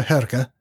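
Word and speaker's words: Hårga: How is it pronounced Haga? – Uh, Herga. That, that one.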